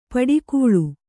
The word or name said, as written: ಪಡುಕುಳಿ